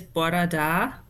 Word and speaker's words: bora da: Good morning is bora da.